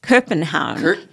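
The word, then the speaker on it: København: My Danish friend, I do have a Danish friend, said Kopenhagen.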